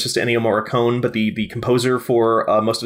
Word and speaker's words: Morricone: If you want to do it like the Italian way, it would be Morricone, but Morricone.